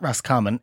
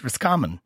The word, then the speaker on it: Roscommon: And actually, I was told before I came down here that it's not Roscommon, it's Roscommon. So I was saying it wrong, apparently. – Roscommon. – Instead of Roscommon, I was saying it as two words.